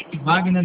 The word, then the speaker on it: Wagner: Wagner da Silva Cedinho. – Como se escreve Wagner? É com V ou com W? – Com W.